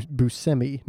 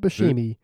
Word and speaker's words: Buscemi: Buscemi, not Buscemi. – It's Buscemi? Oh, okay. – Well, that's how he pronounced it. I assume he would know.